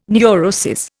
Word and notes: neurosis: Indian

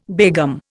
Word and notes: begum: Indian

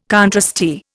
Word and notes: contrasty: Indian

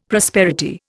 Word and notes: prosperity: Indian

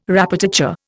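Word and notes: répétiteur: Indian

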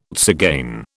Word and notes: tzigane: British English

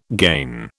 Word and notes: gain: British English

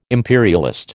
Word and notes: imperialist: American English

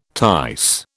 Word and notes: tice: British English

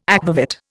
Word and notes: akvavit: Indian

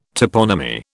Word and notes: toponymy: British English

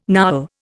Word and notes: Nowel: Indian

